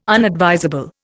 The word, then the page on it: unadvisable: Indian